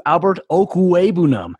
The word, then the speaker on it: Okwuegbunam: I'm going to pronounce the Mizzou tight end for you, Albert Okwuegbunam.